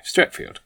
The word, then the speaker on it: Streatfeild: Is it pronounced field or is it pronounced Feld? I don't know. – No, it's pronounced field. – Okay, Streatfield, which is one of her novels, not as opposed to one of her children's books.